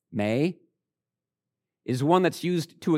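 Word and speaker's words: may (μή): The word not in the original Greek, two letters, M-E. It's pronounced may.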